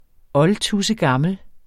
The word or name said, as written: oldtudsegammel